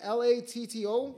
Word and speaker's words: Latto: Is that how you say it? L A T T O. Yeah, Latto. Okay, I thought it was another one, but song man.